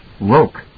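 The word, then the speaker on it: roque: "It's pronounced roke," Daddy was saying.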